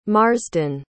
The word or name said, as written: marsden